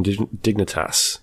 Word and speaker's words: Dignitas: and they'll be managing under Team Dignitas. Dignitas? Is that how you pronounce it? Yeah.